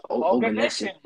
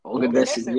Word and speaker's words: Oganesson: Yeah, Oganesson, bro. – Oganesson, yeah.